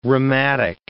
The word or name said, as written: rheumatic